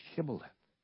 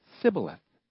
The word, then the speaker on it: Shibboleth: In the same way, the Ephraimites had a problem pronouncing that soft SH, Shibboleth. Instead, they said Sibboleth.